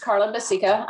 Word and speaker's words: Carla Bacica: So yes, my name is Carla Basica.